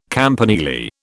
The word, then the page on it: campanile: British English